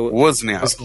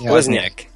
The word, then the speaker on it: Wozniak: Wozniak. Wozniak.